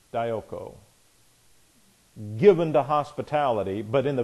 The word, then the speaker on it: dioko: But even in looking at the Greek, this is a Greek word, dioko, D-I-O-K-O, dioko. This is D-I-O-K-O, dioko. These are both long O's, omegas.